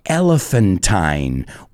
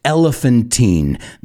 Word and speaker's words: elephantine: And that word is elephantine, or it can be pronounced elephantine. That's up to you. Depends if you're in the United States or England.